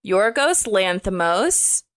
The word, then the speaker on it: Yorgos Lanthimos: It's directed by Yorgos Lanthimos. I'm sure I'm not saying that right, but I think that's right.